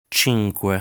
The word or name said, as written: Cinque